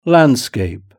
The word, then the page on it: landscape: British English